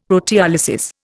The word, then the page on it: proteolysis: Indian